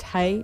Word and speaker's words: Hej: I'm assuming the song is pronounced Hey. It's spelled H-E-J. But if you listen to the lyrics, they say Hey a lot.